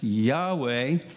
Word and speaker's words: Yahweh: It's been pronounced Yahweh.